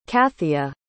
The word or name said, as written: cathia